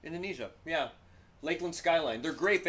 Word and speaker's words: Lakland: I think it's just Lakland. – Not Lac. – No. – Lakland. – Lakland. Because I think they have a little thing on the A. Lakland. Lakland. Like a lake. And that is a... It's a long A. – It's a long A. So, yeah. Long, sharp A.